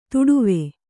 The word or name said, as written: ಧಡವೆ